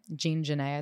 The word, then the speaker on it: Jean Genet: Jean Genet, I'd say. – Okay. I didn't know if that was Jean in French. – Yeah, I feel like you could be like Jean Genet.